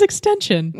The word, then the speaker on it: XXXTentacion: His name is Extension. It's just Extension. It's just Extension. It's not XXXTentacion.